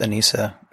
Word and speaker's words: anicca: Getting used and normalizing and embracing, whereas it's pronounced anicca, impermanence, is one way to deal with change.